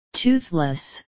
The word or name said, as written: toothless